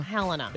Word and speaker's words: Helena: Do you pronounce it Helena? – Helena. – It's Helena. – I think so. – Yeah, it is. – I mean, don't ask me. I mispronounce everything. – No, it's Helena. It is.